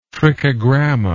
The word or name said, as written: trichogramma